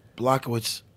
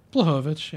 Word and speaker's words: Blachowicz: Blachowicz decision. – Okay. Wow. All right. – Wait. I pronounced his name right. – Blachowicz, yeah. – Call Blachowicz. Blachowicz. – It's okay. You're anglicizing it. It's all right.